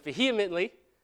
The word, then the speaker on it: vehemently: He made it into two syllables somehow. I don't know how. But it's vehemently, okay?